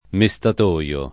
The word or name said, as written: mestatoio